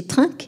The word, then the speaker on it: Trink: qui s'écrit T-R-I-N-K, donc on peut prononcer trinque évidemment, euh, et qu'on traduit par bois.